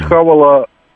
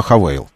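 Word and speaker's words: Haval: Вот хавала, короче, литр на 1000 километров. – Правильно говорить не хавал, а хавейл, так называется этот бренд.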